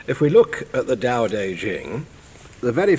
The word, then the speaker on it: Tao Te Ching: Well, we get the name from primarily, and the information or understanding of it primarily from a book called the Tao Te Ching, T-A-O-T-E-C-H-I-N-G, pronounced Tao Te Ching.